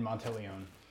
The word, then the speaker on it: Monteleone: Father David Monteleone.